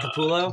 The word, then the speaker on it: Capullo: Greg Capullo.